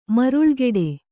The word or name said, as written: ಮರಳುಗಾಡು